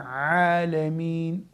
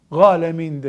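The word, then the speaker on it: alemin (العالمين): Mesela ayn diye bir harf vardır. Ayn olur o sadece. Rabbil alemin olur. Buna işte biz çok zor alemin diyoruz. Galemin desek Kur'an olmaz bu.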